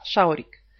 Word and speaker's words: schaurig: Помните, как буква «ш» пишется в немецком? «СЦХ» – «шаурик».